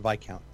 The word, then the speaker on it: Viscount: It's pronounced Viscount. The S is silent, like an island.